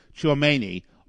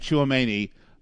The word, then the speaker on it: Tchouameni: And I actually got his name right. I always say it and it sounds like I'm chewing tobacco. Tchouameni. Aurelien Tchouameni.